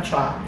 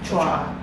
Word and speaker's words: Choi: Hindi naman daw Choi yung pronunciation noon, parang Chua. Chua.